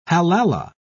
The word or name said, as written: halala